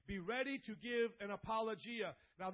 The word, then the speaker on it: apologia: This is apologia. That's exactly how you pronounce it.